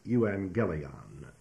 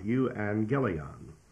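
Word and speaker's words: euangelion (εὐαγγέλιον): And to state it simply in the Greek language, when two G's come together, one takes the N sound, and therefore this word, euangelion, would be pronounced euangelion. Euangelion. And you will notice in there that there is in this the word angel, A-N-G-E-L.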